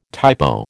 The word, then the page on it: typo: American English